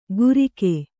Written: ಗುರಿಕೆ

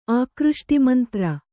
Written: ಆಕೃಷ್ಟಿಮಂತ್ರ

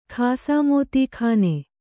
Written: ಖಾಸಾಮೋತೀಖಾನೆ

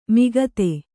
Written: ಮುಗ್ಧ

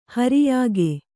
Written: ಹಿರಿಯಕ್ಕ

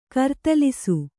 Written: ಕರ್ತಲಿಸು

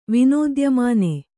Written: ವಿನೋದ್ಯಮಾನೆ

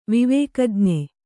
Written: ವಿವೇಕಜ್ಞೆ

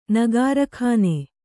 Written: ನಗಾರ್ಖಾನೆ

